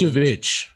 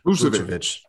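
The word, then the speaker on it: Vučević: Tell me, how do you pronounce Vucevic? – Vucevic. Thank you.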